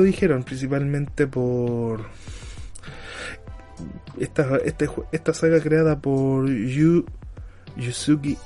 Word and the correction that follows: Yu Suzuki: ah, por Yuzuki, sí, Yuzuki, ahí está, aprende a hablar mono aprende a pronunciar bien el japonés. Yu Suzuki, ahí está.